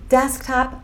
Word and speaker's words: desktop: Mas essas palavras aqui, desktop, laptop ou tablet, duas sílabas só.